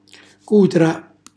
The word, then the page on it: cutra: calabrese